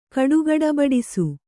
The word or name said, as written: ಕಡುಗಡಬಡಿಸು